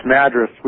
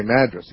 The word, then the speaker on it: Madras: Yeah, it's Madras we pronounce Madras. – Here in Oregon. – Okay, Madras.